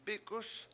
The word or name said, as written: bIk7us